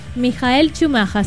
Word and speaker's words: Michael Schumacher: Es que la Gaby Villalobos me dijo que había que pronunciarlo así, Michael Schumacher.